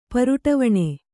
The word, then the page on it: ಪರುಠವಣೆ: paruṭhavaṇe